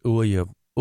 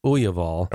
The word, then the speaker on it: Aughaval: Um, they pronounce it Uyav, Uyaval. Okay. Um, but it looks like Aughaval.